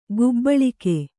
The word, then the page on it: ಕಬ್ಬುಳಿಗ: kabbuḷiga; Kannada